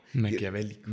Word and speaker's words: maquiavélico: ¿Qué, qué, qué hijo de maquiavólico? Maquiavélico. maquiavólico. Aquí hashtag maquiavólico. ¿Cómo, se escribe Green? Pasa eh, P-A-S-S-E.